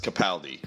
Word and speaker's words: Capaldi: Capaldi is incredible, and it is pronounced Capaldi. We've been saying Capaldi, but it is Capaldi.